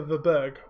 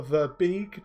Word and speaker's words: Verbeeg: It's called a Verberg, or Verbeeg, depending on how you want to say it. It's spelled V-E-R B-E-E-G. – E-E-G, thank you.